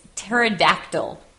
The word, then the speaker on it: pterodactyl: It's pterodactyl. – Pterodactyl. That's a strange one. So it starts with a P, but the next letter is a T. We don't pronounce the P, we pronounce the T. Pterodactyl.